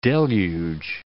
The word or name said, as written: deluge